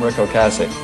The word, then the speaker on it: Ocasek: But then I go on YouTube to figure out how to say his name. And in this little video clip, he says, "Hi, I'm Rick Ocasek."